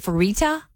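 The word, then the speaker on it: Fertitta: The brothers, I kind of know how to say their name. It's F-E-R-I-T-T-A. Is it Ferita? – Yeah.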